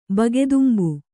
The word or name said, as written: ಬಗೆದುಂಬು